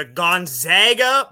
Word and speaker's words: Gonzaga: The Gonzaga Bulldogs. – Gonzaga. – It's not the Zags because they're the Zagas. They're not the Zogs.